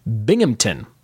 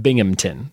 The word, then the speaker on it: Binghamton: Locals don't pronounce the H. It's all sort of slammed together like Binghamton. Binghamton. So he put Bing-M-T-O-N. Just say it a little quick and you should have it.